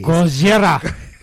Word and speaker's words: Gojira: You have to say it like you're Japanese. – Gojira.